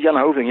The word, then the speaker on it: Jan Hoving: Because the first one had, you know, the rock moments and stuff, and what I like about the vocalist, and correct me if I say his name wrong, but Jan Hoving. – Yeah, that's pretty good, actually, for an American? – For a non-Dutch. Yeah.